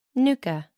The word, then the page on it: nucha: American English